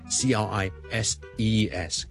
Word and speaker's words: crises: 今日咧听咗文少叔叔 crisis, C-R-I-S-I-S, 一个危机 crisis。佢个 plural form, 佢唔系 C-R-I-S-I-S 再 E-S。而是 C-R-I-S-E-S，那他文字应该怎么读？OK， crisis 呢是 singular， crisis 一个危机，crisis，但是有 multiple 的危机呢，我哋叫做 number of crises， OK，之前那个 crisis，而家后面那个是 E-E-E crises， OK， number of crises， so one crisis， two crises。